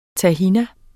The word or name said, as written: tahin